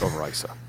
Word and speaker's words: Overijse: We do too many it's, It's pronounced over ISA.